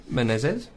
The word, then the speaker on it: Menezes: Menezes.